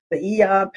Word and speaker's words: Aeon: I was telling everybody about the Eon patches. It's spelled A-E-O-N. But I was telling everybody about the Eon patch.